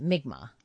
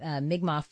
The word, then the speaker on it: Mi'kmaq: He's you pronounce it Mi'kmaq. – Mi'kmaq from the Millbrook First Nations in Nova Scotia.